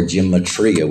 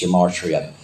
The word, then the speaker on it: gematria: So what I did when we began to study the scripture many, many, many years ago, I was taught the art of gematria, or gematria, whichever way you want to pronounce it, but it's gematria.